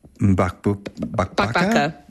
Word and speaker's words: Mbakbaka: There's another recipe, and boy, I'm not confident that I will pronounce this correctly, but it's the one pan Mbakbaka. That's it. You did well. That's it. Mbakbaka.